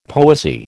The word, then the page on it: poesy: American English